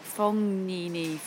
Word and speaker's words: Fognini: Well, just before we go any further, let's just have a pronunciation consensus on Fabio Fognini, or Fognini. There's no G in there, is there? – I sort of say like a very soft G, like Fognini, Fognini.